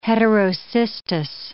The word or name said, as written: heterocystous